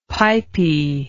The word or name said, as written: pipy